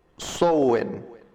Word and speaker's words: Samhain: El hombre pagano de este evento era Samhain, pronunciado Sowen.